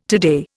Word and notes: today: Indian

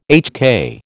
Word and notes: HK: American English

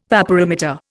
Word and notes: vaporimeter: Indian

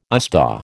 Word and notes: USDAW: American English

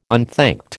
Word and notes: unthanked: American English

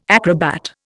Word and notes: acrobat: Indian

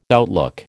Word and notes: outlook: American English

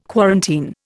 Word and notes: quarantine: Indian

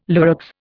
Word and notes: Lurex: Indian

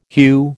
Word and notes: hue: American English

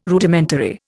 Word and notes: Rudimentary: Indian

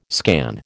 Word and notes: scan: American English

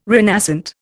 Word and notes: renascent: Indian

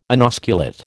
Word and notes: inosculate: American English

